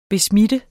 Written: besmitte

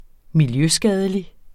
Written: miljøskadelig

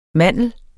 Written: mandel

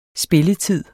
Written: spilletid

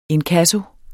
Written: inkasso

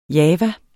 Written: java

